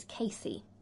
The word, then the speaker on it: CACI: Okay, so I still like Microcurrent, which I suppose a brand name for that people might know is Casey. Okay. Or Kaki, as some people pronounce Kaki. It. That's okay. – How it's... – C-A-C-I.